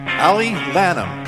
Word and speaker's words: Allie Lanham: Please welcome Allie Lanham.